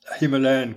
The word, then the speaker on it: Himalaya: And the general consensus nowadays seems to be that Himalaya, with a long first A, identifies a region, whereas Himalayas is a collection of mountain ranges.